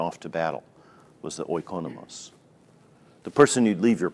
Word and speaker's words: oikonomos: Oikonos, how do you say that? Oikonomos.